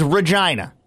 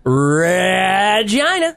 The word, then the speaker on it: Regina: Capital city of Saskatchewan, Canada is Regina. Yes, pronounced like vagina. – Regina.